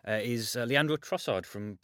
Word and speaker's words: Trossard: Yeah, we don't pronounce the D, though. It's a bit like Giroud. You don't say the D. – So Trossard. – Trossard, yeah. You stop at the R.